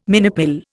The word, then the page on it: minipill: Indian